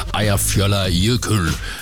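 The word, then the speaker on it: Eyjafjallajökull: Eja fjolla jökull. Mener jeg jo, at den korrekte udtale på et islandske vulkan, der gik i udbrud herover, skabte en askesky, der nedlagde flytrafik i massevis.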